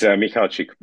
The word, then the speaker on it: Michalczyk: Adrian, how do I say your last name again? – It's Michalczyk, but that's all right, you know. If you will, just pronounce it a little bit in more American way, you know, like it's absolutely okay for me.